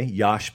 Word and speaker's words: Josh: My name is Josh Pate. Or you can go Soft J, Yosh Pate. That works as well.